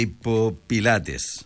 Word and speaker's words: hipopilates: Doncs dissabte, dia 15, taller de hipopilates. No sé si ho pronuncio bé. Hipopilotes. – Hipopilates. – Pilates, això.